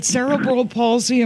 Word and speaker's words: cerebral palsy: Jason, with cerebral palsy, is it cerebral palsy? Am I pronouncing it correctly? I've always wondered. – Yes.